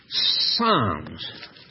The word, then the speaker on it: Psalms: Psalms. Silent P.